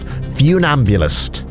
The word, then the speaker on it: funambulist: Funambulist is spelled like fun. Funambulist. That's the trip you up with the pronunciation, but it's pronounced funambulist.